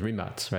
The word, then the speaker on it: Rimac: So by the way, I mean, in the press worldwide, it's called Rimac, but really the Croatian pronunciation is Rimac, right? – Exactly.